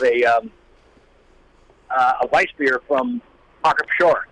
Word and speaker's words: Pschorr: You guys, it's spelled P-S-C-H-O-R-R, and in German, in English, you just pronounce it shore, but in German, you pronounce it shore.